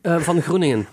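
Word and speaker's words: van Groeningen: Did I pronounce it well? Groningen? – It's von Groningen.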